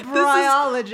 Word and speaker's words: bryologist: I thought you just were saying biologist with like an R stuck in there. I was like, what? Did we need to redo that? I'm a bryologist.